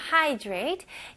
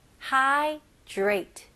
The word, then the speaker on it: hydrate: Hydrate. 이거는 발음을 hi, 그러니까 hi 할 때 hi. 그 다음에 drate. drate는 이름절로 읽으셔야 돼요. drate 뭐 이런 식으로 하시면 안 되고요. drate. 여기서는 d 발음보다는 d가 r랑 같이 합칠 때 j 발음이 많이 나와요. 그렇게 읽으셔야지 훨씬 더 쉬워요. 그래서 drate. 원어민들도 힘들거든요. 그래서 j 발음으로 drate. drate. hydrate.